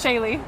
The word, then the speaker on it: Shailey: I have Miss Shaylee. – Shaylee, how do you spell that? – S-H-A-I-L-E-Y. – I-L-E-Y. – Like Haley, but with, like, with an S.